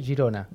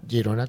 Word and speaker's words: Girona: Almudena Ortega de Murcia y Jordi Rocha de Girona, que se escribe Girona, pero se lee Girona. – Girona, ¿Mm?